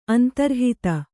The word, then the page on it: ಅಂತರ್ಹಿತೆ: antarhite; Kannada